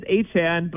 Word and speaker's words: Achane: And there was a video from his time in college where he said, you know, it actually is Achane, but the other way is fine too.